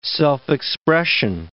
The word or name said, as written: self-expression